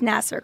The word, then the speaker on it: Nasar: It's pronounced Nasar, correct? – You pronounced it perfectly. – It's like NASA with an R.